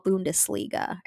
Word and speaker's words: Bundesliga: And I'm probably saying this wrong, but, like, the Gießen 46ers in the German basketball Bundesliga.